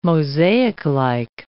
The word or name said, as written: mosaiclike